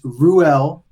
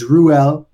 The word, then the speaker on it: Reuel: It's Reuel Pendleton Belt or Rue. Some of my close, dear friends that know me for quite a long time, they call me Rue, but it's Reuel.